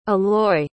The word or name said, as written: Eloy